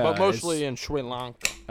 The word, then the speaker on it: Sri Lanka: Sri Lanka? – Yeah, Sri Lanka.